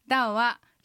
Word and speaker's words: 道: ダオはひらがなで書くと、まああえて書くとダオに近い感じですね。